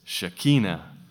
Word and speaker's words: Shekinah: And now that I can sort of read Hebrew a bit, I find that actually I've been saying it wrong all my life. It's Shekinah.